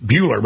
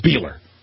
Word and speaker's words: Bueller: Everybody I know says you pronounce it Bueller, but he goes, no, it's Beeler.